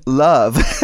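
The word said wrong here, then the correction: Lova: It's actually a Swedish name, Luva. – Luva, see, of course, see, yeah.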